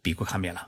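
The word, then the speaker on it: ビックカメラ: 如果你要购买照相机、电饭煲、感冒药等家电家用商品，与银座相连的有乐町有一家很大的电器商店，叫碧库，日文是念作是比库卡面了。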